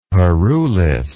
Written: parulis

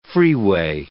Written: freeway

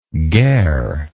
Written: gare

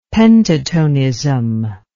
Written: pentatonism

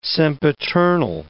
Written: sempiternal

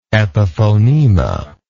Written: epiphonema

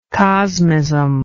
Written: cosmist

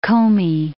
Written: Komi Republic

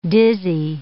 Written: dizzy

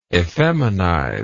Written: effeminization

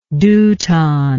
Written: deuton

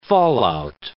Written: fallout

